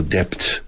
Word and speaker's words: debt: He oído debt demasiadas veces en mi vida aquí. And people who speak English well, Spanish people, but they say, nobody told them that debt, the B is silent.